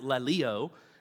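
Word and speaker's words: laleo (λαλέω): And it's important for us to note here, the Greek word for teach, which we just read, is pronounced laleo, and it means to speak, to talk, to utter.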